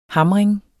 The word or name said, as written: hamring